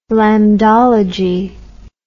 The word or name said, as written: lambdology